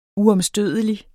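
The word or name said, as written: UOMSTØDELIG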